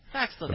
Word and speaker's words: Paxlovid: Paxlovid.